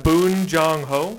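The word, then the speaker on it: Bong Joon-ho: Boon Jong-ho.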